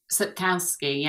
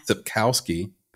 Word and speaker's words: Sapkowski: Is it Andrzej Sapkowski? – Sapkowski, yeah. – Sapkowski.